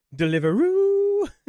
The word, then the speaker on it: Deliveroo: So I'm guessing over there, it's Deliveroo. – Yes. Your Majesty, where shall we have the food ordered in this evening? Deliveroo!